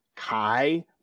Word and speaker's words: chi: And for those of our listeners who haven't run into that terminology before, chiastic, really you think about the Greek letter chi, which is in the Greek alphabet, is an X. Okay, it looks like an X. It's pronounced like a CH, but it's an X.